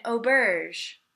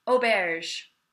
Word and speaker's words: auberge: And it happens to be an auberge. Auberge. – Auberge. – Which is a hotel. – Okay. – So. – Auberge.